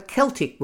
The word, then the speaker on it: Celtic: I said that Britannia likely comes from a Celtic word, the same spelling, C E L T I C, and the same origin are in the differently pronounced Scottish football club Celtic.